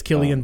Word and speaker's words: Cillian: I but, think it's Killian, um, but I don't fucking know.